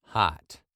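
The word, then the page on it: hot: british-english-pronunciation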